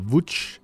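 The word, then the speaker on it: Łódź: Kort før 2020 blev til 2021 skiftet Mikkel Rygaard fra FC Nordsjælland til polske LKS Lodz, eller Wuch, som Rygaard indfører mig i, at det udtales på polsk.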